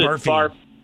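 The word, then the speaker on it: Barfée: Yeah, but they pronounce it Barfay.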